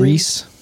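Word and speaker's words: Rhys: Reese.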